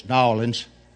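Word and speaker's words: New Orleans: Being a southerner, I realize that the church has furnished us the car because it's a long way between he pronounced that name wrong, by the way. It's Nawlins.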